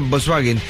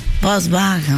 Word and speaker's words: Volkswagen: Un día como hoy se fundaba la empresa de fabricación de automóviles alemana Volkswagen. Ajá. Qué difícil de decir, lo fácil que lo solucionamos, ¿no? Volkswagen. – Sí, algunos dicen Volkswagen. – Que la pronunciación correcta en alemán.